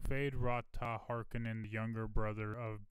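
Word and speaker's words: Feyd-Rautha: Is that how you say it? It's Feyd dash Rautha.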